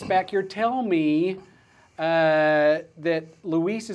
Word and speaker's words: LUIS: You can even go out to Cortana or Siri and say, how do I pronounce L-U-I-S? And it'll tell you how to pronounce that if there's any questions. So if you guys are out there saying it's Lewis, Lewis, Lewis, it's not. It's Luis.